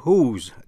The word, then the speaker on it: whose: Cũng phân biệt chữ whose, W-H-O-S-E, của ai, phát âm giống như whose, W-H-O apostrophe S.